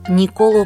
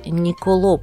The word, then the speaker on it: Николо: На самом деле великого итальянского скрипача и композитора звали Николо Паганини, но в русском языке ударение в итальянских именах, как правило, ставится на второй слог «от конца». Так что в нашей традиции он утвердился под именем Николо.